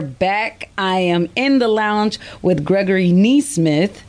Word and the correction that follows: Nesmith: because I've been saying his name wrong this whole time, y'all. Neesmith. Gregory Neesmith.